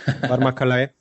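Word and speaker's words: Varma Kalai: You pronounced that very, very nicely, very well, Vama Kalai.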